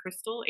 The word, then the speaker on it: Kristal: I'm at my full name, Hayden Kristal, HayDenKristal. It's pronounced like the meth, but it's spelled like a stripper, so it's easy to remember.